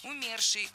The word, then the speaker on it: умерший: Много раз прозвучало причастие «умерший» с ударением на первом слоге, но это неверно. В этом слове ударение должно падать на второй слог «умерший», «умершие», «умерших».